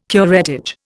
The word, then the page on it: curettage: Indian